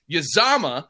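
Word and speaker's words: Uzomah: I'm trying to make sure I'm saying this right. Uzama. Okay. Uzama.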